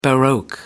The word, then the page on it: baroque: American English